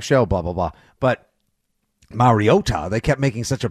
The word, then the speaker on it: Mariota: Like you said, number 1, you can hold down even after last night's, oh God, I've never wanted Marcus Mariota to complete, excuse me, Mariota.